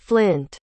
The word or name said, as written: flynt